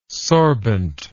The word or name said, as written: sorbent